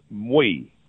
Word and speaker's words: Moi: It's Mui. It's think of the term moi, like my in French. That's the way it's spelled. – But not moi. – Not moi. Yeah, it's spelled M-O-I. – Yes, M-O-I. – But it's pronounced like the Jewish moi.